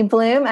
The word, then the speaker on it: Bluhm: Tiffany Bluhm, as Alexa said so well, thank you for pronouncing my last name correctly.